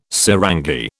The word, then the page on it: sarangi: British English